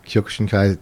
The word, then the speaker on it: Kyokushinkai: What do you think about some of the other styles, like Kyokushinkai, how do you say that? Kyokushin? – Yeah, Kyokushinkai.